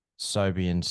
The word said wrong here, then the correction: Siobhan: Oh, Siobhan.